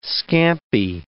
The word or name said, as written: scampi